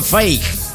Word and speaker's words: fake: Faque se escribe, ¿no? Faque, dijo Anselmo Lozano. – Faque se pronuncia fake.